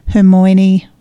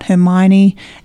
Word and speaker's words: Hermione: This is centred around Harry, Hermione, I can never say it right, Hermione, and Ron Weasley, and it's centred around their children.